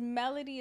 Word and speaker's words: Melodie: My name is Melody. It's an acronym. It's spelled M-E-L-O-D-I-E.